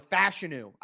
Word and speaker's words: Fashanu: And let's say this, a lot of you guys, including myself, want Joe Alt or Olafashanu, or Fashanu, I guess, is the proper pronunciation.